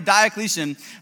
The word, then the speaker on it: Diocletian: There are still 5,700 manuscripts that we know exist, despite the fact that in 303 AD, the Roman Emperor Diocletian, let me make sure I'm saying that right. Yep, the Roman Emperor Diocletian made a decree that all copies of everything related to the Bible would be destroyed.